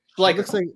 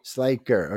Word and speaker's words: Schleicher: Schleicher. – Say it again. – Schleicher.